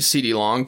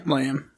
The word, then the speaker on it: Lamb: And Kyler Murray drops back, just a little 3-step drop or something like that, and I see CD Long just blow by everybody. – Lamb.